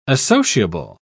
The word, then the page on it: associable: British English